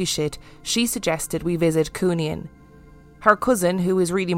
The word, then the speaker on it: Cooneen: My wife is from County Fermanagh, and I promised that I would let you know the pronunciation is Cooneen.